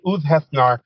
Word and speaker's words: Ulfhethnar: Ulfidnar? What does that mean? – Close, close. You got Ulv, OOLV, Ulv. Heth is HETH, and then NAR. And Ulfhethnar, those were warriors, according to Norse legend.